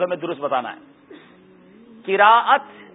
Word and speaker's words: قراءت: قراءت، قراءت لوگ کہتے ہیں قرت کر رہا تھا، قرت لفظ درست نہیں ہے، لفظ ہے قرآن پاک کے لیے جو استعمال ہوتا ہے وہ قراءت ہے، اور قراءت صحیح تلفظ ہے.